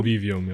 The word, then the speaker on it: Convivium: Spricht man das so aus? Convivium? – Convivium, ja.